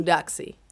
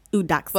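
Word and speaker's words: Eudoxie: How do you pronounce his wife's name? Edoxy? – Udoxy. – Udoxy.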